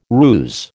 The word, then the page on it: ruse: American English